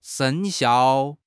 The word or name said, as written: 生效